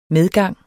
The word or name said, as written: MEDGANGS